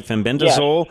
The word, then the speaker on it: fenbendazole: I don't know if I'm saying that right. Fenbendazole.